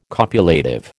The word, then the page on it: copulative: American English